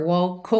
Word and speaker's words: walk: Primeiro, walk termina em K, então naturalmente você junta walk over, walk over. Uhum. A segunda coisa é você perceber que a gente não fala walk, walk, ok? Se escreve W-A-L-K, mas o som é meio walk, walk, ok?